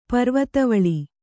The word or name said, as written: ಪರ್ವತಾವಳಿ